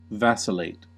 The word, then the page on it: vacillate: American English